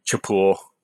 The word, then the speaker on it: Chapul: So your company is, how do you pronounce it actually? Is it a hard ch or sh? – Yeah, hard ch. Either one. Chapul.